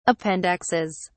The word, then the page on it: appendixes: British English